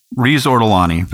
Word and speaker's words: Riz Ortolani: Riz Ortolani.